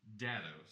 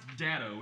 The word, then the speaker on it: Daddy-o: Daddy-o is the word Irish children use for grandfather, and it's pronounced daddo. Sorry, I misread it just a moment ago. This is a cold read, an honest cold read. So it's pronounced daddo when you come across it in the script, daddo.